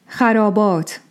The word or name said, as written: Xarābāt